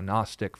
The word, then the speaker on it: Gnostic: Now, Gnostic, for those of you who speak English, think of the term like gnome. It starts with a G, but the G is silent.